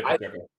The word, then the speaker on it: Pacheco: Yeah, Isaiah Pacheco.